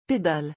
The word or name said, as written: pedale